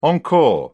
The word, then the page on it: encore: British English